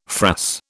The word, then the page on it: frass: British English